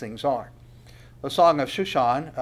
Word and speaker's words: Shushan: probably the way you pronounce this is the Shushan, according to the Shushan, a maskil of the sons of Korah, a song of love.